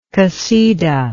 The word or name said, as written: qasida